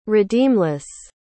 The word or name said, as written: redeemless